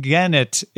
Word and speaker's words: Genet: And Genet is how they say it in the book. But you're right. It looks like Eng. Well, no, Eng. It's not a J. Eng. And Genet Miroway. – Genet Miroway. – And actually, I think in the audiobook, it's more like Genet. – Genet. – Like not even Genet. Much of a second syllable there.